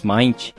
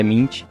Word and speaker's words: Mint: um dos convidados nossos que falou Linux Mint e a pronúncia correta é Mint.